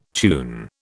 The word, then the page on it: tune: British English